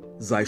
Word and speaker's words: sei: You could say, sei still. Sei, that's S-E-I, still, or Ruhe, which simply means silence, capital R-U-H-E, Ruhe.